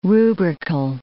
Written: rubrical

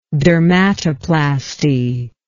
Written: dermatoplasty

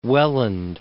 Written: Welland

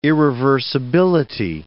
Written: irreversibility

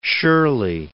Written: Shirley

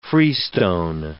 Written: freestone

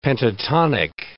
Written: pentatonic

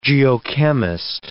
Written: geochemist

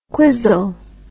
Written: quisle

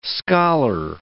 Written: scholar

